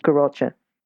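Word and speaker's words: Garotcha: but just briefly talk about Garocha. And that's spelled just for everyone, it's G-A-R-O-T-C-H-R. C-H-A. – C-H-A, that's yep.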